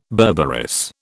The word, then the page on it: berberis: British English